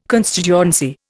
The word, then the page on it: constituency: Indian